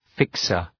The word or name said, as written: fixer